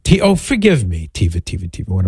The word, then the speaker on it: Taoimha: I mean, if you were to look at how it's spelled, T-A-O-I-M-H-A, you would not think, unless you were from Ireland, you wouldn't think Tiva, but that's how I guess it's pronounced. T-A-O-I-M-H-A spells Tiva.